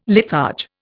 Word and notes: litharge: Indian

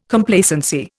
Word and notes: Complacency: Indian

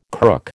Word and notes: crook: American English

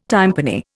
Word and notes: tympani: Indian

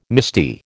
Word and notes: misty: American English

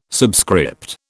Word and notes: subscript: British English

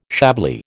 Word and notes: Chablis: American English

